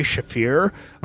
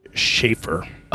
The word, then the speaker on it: Shaffir: Like, I'm a bit Ari Shaffir. Like, okay. – Yeah, Mr. Man had to correct me on my pronunciation of Shaffir.